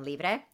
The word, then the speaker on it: Libre: Did I say it wrong? Maybe I... – No, you did. – Yeah, okay. – No, you didn't. I mean, you didn't say, you said it correctly. Libérame okay. is the song, and it's from the album Libre.